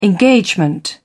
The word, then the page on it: engagement: British English